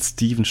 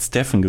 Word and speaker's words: Stephen: Und der Typ von Adidas hat seinen Vornamen falsch ausgesprochen, hat Steven statt Stephen gesagt. Und daraufhin haben Stephen Curry und sein Vater gesagt, irgendwie fühlen wir uns hier nicht ganz wohl.